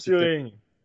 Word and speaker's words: Turing: Turing.